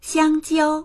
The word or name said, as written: xiang1jiao1